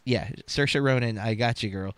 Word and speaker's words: Saoirse: I will be walking and I'll just be like, Saoirse like inertia. Saoirse like inertia. Those are her words from a video that I watched to learn how to say her name.